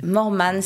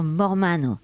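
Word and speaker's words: Mormanno: Mormane, c'est là où vivaient mes parents. – Avec la prononciation française, on pourrait dire Mormano.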